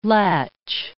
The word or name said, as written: latch